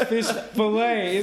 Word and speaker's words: filet: It was fish olé. He said fish olé. – Fish olé. – And it's fish filet. It's fish on the filet.